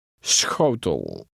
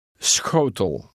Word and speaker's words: schotel: Schotel. – The letter combination SCH is pronounced with a slight S sound followed by a Dutch CH sound. – Sch. – Read 17 again. – Schotel.